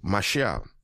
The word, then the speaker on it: mashal: The word parable, the transliteration is Mashal. All right? Mashal. It is spelled M-A-S-H-A-L. M-A-S-H-A-L.